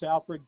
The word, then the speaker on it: Alfred: It's Alfred G. Altamari. – You said that was Alfred? – Yep. – Will you spell that first name? – A-L-F-R-E-D, Alfred G, initial, Altamari, A-L-T-O-M-A-R-E.